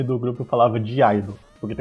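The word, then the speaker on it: (G)I-DLE: E aí, depois eu vi que, na verdade, pronuncia apenas idol, que o G é mudo.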